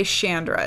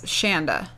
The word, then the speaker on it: Shanda: But they don't ever say Chandra. It's Shanda. – Shanda? – Yeah, it's miscredited on IMDb because on the credits of the movie, it's Shanda. – Okay, I was only one letter off. – Rhymes with panda.